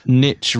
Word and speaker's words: niche: Niche rhymes with itch, says Jonathan.